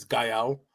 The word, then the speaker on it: Gael: well, it's funny because I realized like that's the actual pronunciation. Pronunciation is Gael.